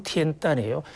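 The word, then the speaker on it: 티엔단이에요 (天坛): 중국 베이징에서요 가장 그 역사적 또 하늘과의 연결 면에서 가장 중요한 곳이 바로 티엔단이라는 중국 발음으로는 티엔단이에요. 한국에서는 천단, 천단 하지만 하늘의 단 이런 뜻인데요.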